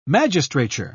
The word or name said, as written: magistrature